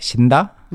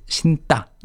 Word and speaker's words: 신다: You also see the spelling 신 and 다. Don't pronounce it like 신다. – Mm-hmm. – It's 신다.